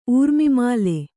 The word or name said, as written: ಊರ್ಮಿಮಾಲೆ